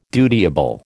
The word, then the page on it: dutiable: American English